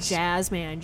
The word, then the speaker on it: Jazzman: It's got like a Louis Armstrong on it and Jasmine, J-A-Z-Z-M-A-N.